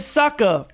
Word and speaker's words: Sokka: He was like, my name is Sokka, pronounced with Akka.